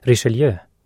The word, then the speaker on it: Richelieu: Richelieu. Namnet på den franske kardinalen uttalas Richelieu.